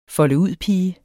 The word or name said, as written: folde